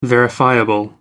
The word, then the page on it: verifiable: American English